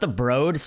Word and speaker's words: Broad: So Thursday we went to, it's pronounced the Broad, spelled the Broad.